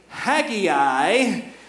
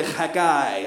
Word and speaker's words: Haggai: Some pronounce it Haggai, but my father, the Hebrew scholar last week, he told us in Hebrew, it's really Haggai.